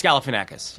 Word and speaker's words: Galifianakis: It's Galifianakis.